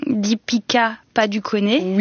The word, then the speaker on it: Deepika Padukone: Deepika Padukone.